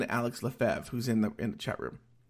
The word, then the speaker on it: Lefebvre: But he spells his name just like Alex Lefebvre, except between the E and the V, there's a B. But he pronounces it Lefebvre as well.